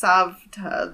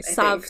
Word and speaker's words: Savta: Also, it's Savta. The V is before the T. – Oh, okay. Sorry. So sorry. Savta.